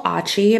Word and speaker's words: Otchy: So I am on Instagram at Nicole Achi. My last name is spelled O-T-C-H-Y.